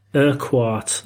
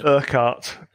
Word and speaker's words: Urquhart: Mine's the Forgotten Highlander, and it's Alistair Urquhart. Hopefully I've said that right. – No, totally wrong. Urquhart.